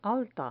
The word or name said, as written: alta